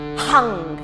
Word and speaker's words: Hung: And the seed syllable for the heart center is Hung, and it's spelled H-U-N-G with an overpronounced H, as if you're, you know, letting out energy through that H.